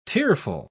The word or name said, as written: tearful